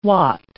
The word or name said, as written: watt